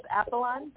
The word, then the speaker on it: Apollon: It's Apollon, but I think people are always confused, so have no fear.